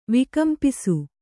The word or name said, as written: ವಿಕಂಪಿಸು